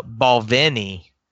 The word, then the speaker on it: Balvenie: And it is, in fact, uh, pronounced the Balvenie. So the emphasis on is on the N part. So the Balvenie.